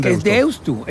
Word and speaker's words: Deusto: ¿Qué haces con Deusto? – Es Deusto, porque se escribe con U final.